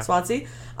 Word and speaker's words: Swansea: Swansea.